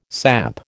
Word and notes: samp: American English

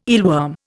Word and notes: eelworm: Indian

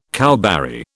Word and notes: cowberry: British English

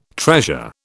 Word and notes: treasure: British English

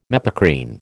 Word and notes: mepacrine: American English

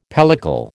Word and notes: pellicle: American English

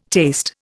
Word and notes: taste: Indian

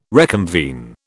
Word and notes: reconvene: British English

